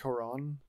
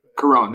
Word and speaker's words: Cuarón: But looking up Alfonso Cuarón, is that how you say it? – I think it's Cuarón, but... – Cuarón, there we go, yeah, okay.